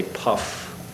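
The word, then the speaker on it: Pfaff: Everybody always has trouble with this name. It's spelled P-F-A-F-F. I like to say Puff, with the Sussex County Development Office.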